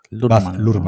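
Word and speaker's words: Luhrmann: Lurman. Lurman, como se escribe, Lurman.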